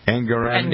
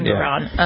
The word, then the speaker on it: Engoron: Yeah, the judge and the his name is Engoron, I think. Isn't that how he pronounced his name? Engoron. – Engoron, uh-huh.